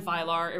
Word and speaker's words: Vilar: It's really not that hard to find Vilar. It rhymes with Mylar.